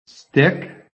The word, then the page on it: stick: American English